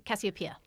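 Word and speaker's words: Casiopea: And it follows our protagonist, Cassiopeia Toon. Did I get her name right? – Cassiopeia. – Cassiopeia. So close enough.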